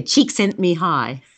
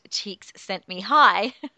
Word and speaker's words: Csikszentmihalyi: and I'll get you to pronounce their name. – And I did pinch that from somewhere, wherever I was reading. I think it was one of the big writers in positive psychology. But, yeah, Csikszentmihalyi. That's how you pronounce it. – So the psychologist, Csikszentmihalyi. – Very good.